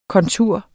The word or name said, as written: kontur